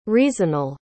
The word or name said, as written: reasonal